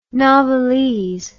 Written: novelese

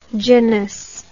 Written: genous

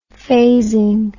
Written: phasing

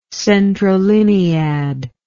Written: centrolinead

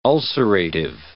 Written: ulcerative

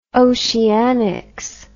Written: oceanics

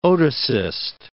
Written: otocyst

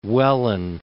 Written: Welwyn Garden City

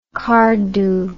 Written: cardhu

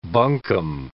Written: bunkum

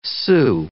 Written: xu

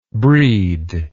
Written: breedable

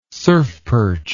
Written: surfperch

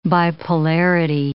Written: bipolarity

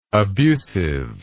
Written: abusiveness

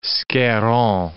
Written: Scarron

